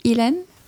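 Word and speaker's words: uilleann: La prononciation de notre instrument en Irlande se dit Hélène Pipe.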